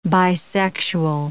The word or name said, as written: bisexual